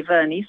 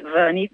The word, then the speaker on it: Verney: Candy Verney, spelt with a C, Candy Verney, V-E-R-N-E-Y, and look up and contact me.